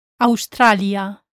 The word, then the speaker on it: Australia: Also watch out for the AU in Australia, Australia.